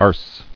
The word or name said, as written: Arse